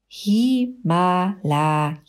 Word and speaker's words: Himalaya: Himalaya, so spricht man das aus.